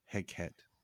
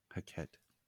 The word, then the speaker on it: Hecate: It's interesting you pronounce it like that, because I've always heard it as Hecate. Hecate.